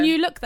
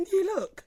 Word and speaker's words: New Look: It says a New Look. – Yeah? – What? – It's a New Look. – New Look. – Yeah, you said New Look. – You're putting the emphasis on the new.